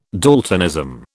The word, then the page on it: daltonism: British English